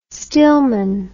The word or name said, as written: stillman